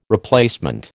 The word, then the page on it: replacement: American English